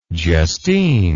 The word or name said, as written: justine